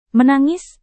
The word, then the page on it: menangis: Indonesian